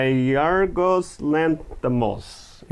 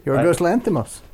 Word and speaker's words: Yorgos Lanthimos: It's a 2009 film by Yorgos Lanthimos. – Yorgos Lanthimos.